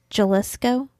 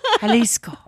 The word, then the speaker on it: Jalisco: Jalisco? – Oh yeah. – Did I say it right? – Yeah. – Jalisco.